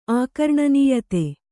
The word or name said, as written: ಆಕರ್ಣನೀಯತೆ